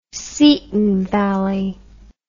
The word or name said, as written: seaton valley